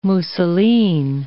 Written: mousseline

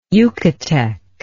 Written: yucatec

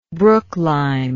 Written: brooklime

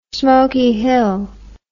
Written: Smoky Hill

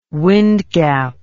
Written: wind gap

